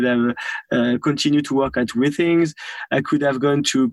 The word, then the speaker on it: Withings: Am I pronouncing it right? Withings? – Yes. Withings, yes. – Withings? – Withings.